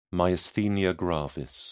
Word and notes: myasthenia gravis: British English

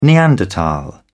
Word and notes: neanderthal: British English

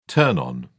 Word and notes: turn-on: British English

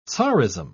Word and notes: tsarism: British English

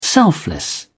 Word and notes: selfless: British English

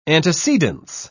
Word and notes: antecedence: British English

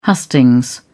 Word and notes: hustings: British English

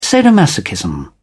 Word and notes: sadomasochism: British English

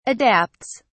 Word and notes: adapts: British English

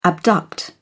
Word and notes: abduct: British English

